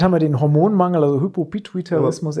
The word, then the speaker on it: Hypopituitarismus: Vom Hypopituitarismus. Genau, Hypopituitarismus.